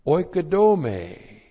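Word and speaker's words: Oikodome (οἰκοδομή): Secondly, it's described by oikodome. O-I-K-O-D-O-M, long E. Oikodome.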